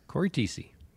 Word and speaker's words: Thiesse: It's Thiesse, right? Korey Thiesse? – Korey Thiesse, yep.